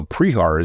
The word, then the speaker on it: Prehar: So Prehar is actually a bastardization of Prehart, which is spelled differently than the P-R-E-H-A-R.